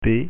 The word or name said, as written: p